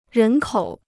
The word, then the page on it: 人口: rén kǒu